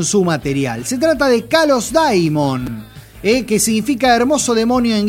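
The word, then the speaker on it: Kalos Daimon (Καλὸς δαίμων): Entonces, cuando uno dice Kalos Daimon, o Kalos Daimon sería la pronunciación correcta, en realidad lo que está diciendo es buen espíritu.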